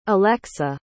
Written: alexa

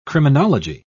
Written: criminology

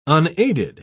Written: unaided

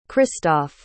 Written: christophe

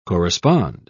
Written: correspond

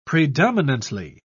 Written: predominantly

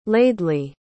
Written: Laidley

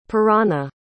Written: parana

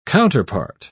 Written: counterpart

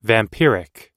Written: vampiric

